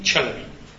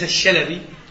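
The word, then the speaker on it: چلبي: مع أخص تلاميذه بعد الشيخ صلاح الدين المعروف بحسام الدين تشلبي، تكتب طبعا بفارسية جيم تحتها 3 نقاط، وهذه تلفظ تشلبي، ليس الشلبي ليس الجلبي، تشلبي،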